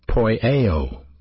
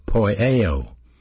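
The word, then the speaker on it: poieo (ποιέω): Well, it's a verb, and it's pronounced poieo, poieo. And in this case, it's a present active imperative verb of poieo.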